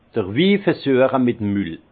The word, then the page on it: d'r Wi versüeche mit 'em Mül: Bas-Rhin; Herrlisheim